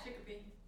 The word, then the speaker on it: Chicopee: Chicopee? – Yeah, Chicopee. – Chicopee. – Wow.